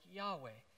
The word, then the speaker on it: Yahweh: But the Hebrew is often pronounced something like Yahweh, and it's built on the word I am.